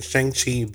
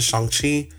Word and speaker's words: Shang-Chi: Um, I know that a lot of us in the western part of the of the world has been saying Shang-Chi, but a lot of people in the Asian culture has been coming out and giving us the proper name of proper pronunciation of that name. So it is Shang-Chi.